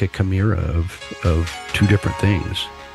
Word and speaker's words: chimera: What about a chimera? I think that's the term, right? When you take two different species and you create a new creature, a chimera.